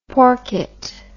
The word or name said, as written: porket